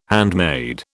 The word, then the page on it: handmade: British English